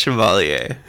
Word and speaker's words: Chevalier: It's pronounced Chevalier. – That's stupid, because like, it looks like Chevalier.